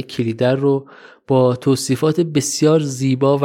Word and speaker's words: کلیدر: خود جناب دولت آبادی توی مصاحبه هاشون که من گوش کردم میگن کلیدر و لذا منم میگم کلیدر